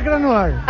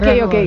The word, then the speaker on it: Krasnodar: Krasnoyarsk, Federación Rusa, Krasnodar. Fuerza. Ah, bueno, porque acá Krasnodar sería entonces... – Sí, en español es Krasnodar. – Ah, ok, Krasnodar.